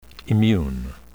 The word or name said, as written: immune